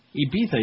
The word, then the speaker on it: Ibiza: Ibiza, you got to pronounce it right and spell it right. You got it, Ibiza? – We'll put it I-B-I-Z-A, on Ibiza in Spain.